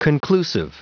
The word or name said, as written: conclusive